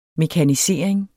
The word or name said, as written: mekanisering